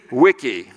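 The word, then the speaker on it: Vici: Mrs. Pichotti taught us to pronounce the V's as W, and so she taught us to pronounce this Wenny, Weedy, Wicky, which translated, Julius Caesar says, I came, I saw, I conquered.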